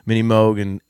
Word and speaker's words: Moog: And he started mentioning, the mini Moog, and which I always thought was a Moog, by the way, but apparently it's a Moog. Or I'm going to go with how Jordan pronounced it.